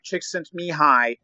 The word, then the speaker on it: Csikszentmihalyi: Mihai Csikszentmihalyi, from uh, originally from Hungary, who later moved to the U.S.